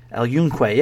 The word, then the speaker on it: El Yunque: How do you say it? Am I saying it right? El Yunque? El Yunque, yeah. The bioluminescent bay is you take a kayak, and it's also a guided tour.